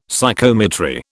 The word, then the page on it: Psychometry: British English